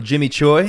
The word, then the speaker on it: Jimmy Choi: Today, we'd like to welcome Jimmy Choi.